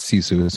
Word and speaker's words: Sisu: I've been mispronouncing it, calling it Sisu, which I was told beforehand that it's Sisu. So I'm glad that you're saying it correctly there.